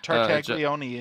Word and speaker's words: Tartaglione: Tartaglione is how that's pronounced.